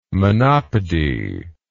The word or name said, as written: monopody